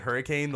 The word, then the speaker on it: Hurricane: We were in Hurricane, West Virginia, spelled just like hurricane. The locals pronounced it hurricane.